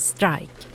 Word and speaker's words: strejk: Det kommer från engelskan strike och så uttalas det ofta. Eller som det stavas, strike.